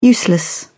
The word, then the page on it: useless: British English